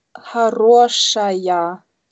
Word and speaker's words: хорошая: Это хорошая идея. Это хорошая idea. Das ist eine gute Idee. – Ja, hier wird äh, zum Beispiel auf хорошая, da wird die Betonung auf это хоро, хорошая идея.